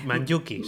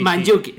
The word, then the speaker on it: Mandžukić: Vale, pues el gol de Croacia, el de Mandžukić, ¿cómo se pronuncia? – Sí, Mandžukić, sí. – Mandžukić, sí.